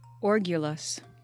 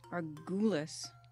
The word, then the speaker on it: orgulous: How do I pronounce it? – Orgulous. – Orgulous. What did I say? Argue? – Argulous. I think you said orgulous, actually.